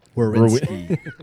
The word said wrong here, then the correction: Werenski: You still said it with the R first. – Werenski.